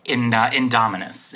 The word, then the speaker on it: Indominus: how much had they invested in the Andromedas? Did I say that right? – Indominus.